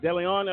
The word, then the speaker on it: DeLeon: Also, Judge Arthur Hunter and Judge James, I'm trying to think how to pronounce it, DeLeon, I believe. Does that sound about right? – Yeah.